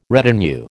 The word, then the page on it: retinue: American English